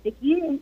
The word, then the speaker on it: Nikki: You can see my author page on our website there, and then I'm also on Twitter at Nikki, N-I-K-K-I, and Schwab spelled like Charles Schwab.